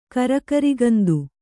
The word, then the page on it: ಕರಿಗರಿಗಂದು: kannada